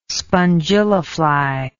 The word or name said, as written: spongillafly